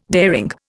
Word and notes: daring: Indian

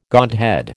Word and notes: godhead: American English

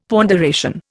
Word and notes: ponderation: Indian

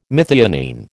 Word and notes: methionine: American English

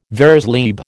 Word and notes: vers libre: American English

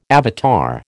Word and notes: avatar: American English

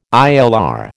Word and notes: ILR: American English

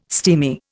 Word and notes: steamy: Indian

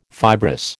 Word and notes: fibrous: American English

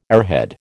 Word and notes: airhead: American English

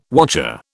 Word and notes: wotcher: British English

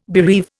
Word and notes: bereave: Indian

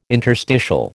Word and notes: interstitial: American English

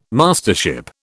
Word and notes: Mastership: British English

